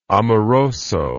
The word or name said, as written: amoroso